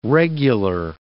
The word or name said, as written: regular